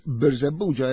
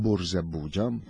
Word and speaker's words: Birżebbuġa: Jiena naħseb kif qed tgħid inti vera, Selin, għaliex Birżebbuġa jgħidu ħafna Burżebbuġa.